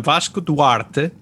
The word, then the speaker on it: Vasco Duarte: Yeah, so I'm Vasco Duarte, and obviously nobody pronounces my name like that, just like I never pronounced the guest's name correctly on the podcast.